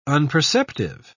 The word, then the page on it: unperceptive: British English